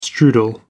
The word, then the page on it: strudel: American English